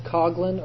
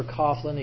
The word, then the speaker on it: Coughlin: Next person, Charles Coughlin or Coughlin. He goes by both pronunciations. Called the Detroit Radio Priest.